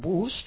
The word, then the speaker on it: bus: Bus, autobús, aunque se escribe igual, B-U-S, un bus, se pronuncia bus. Acordaos de que la U es como si fuera una A, bus, autobús.